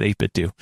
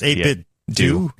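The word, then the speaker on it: 8BitDo: And I'm like, nope, I've talked to the representative multiple times and asked them, how do you pronounce it? And they said 8-bit do. – Really? So it's 8-bit do? – Yes.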